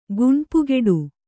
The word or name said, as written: ಗುಣ್ಪುಗೆಡು